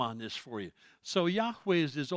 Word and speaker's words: Yahweh: The man's name means Yahweh saves. Yahweh is God's Old Testament name. His name is not Jehovah. There is no J or V in the Hebrew alphabet. They could not have pronounced it, let alone spelled it.